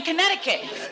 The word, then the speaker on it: Connecticut: This is like the C is silent in Connecticut.